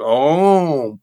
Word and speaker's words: Om: And according to him, and it just felt so resonant, so correct, depending upon the dialect and the people, Om can be pronounced a whole lot of ways. Now, you will find spiritual masters and gurus who say it must be pronounced Aum. Others will say it's Om or whatnot. You can have so many different ways of doing it. And you're totally technically correct that it's actually a three-syllable word in Sanskrit.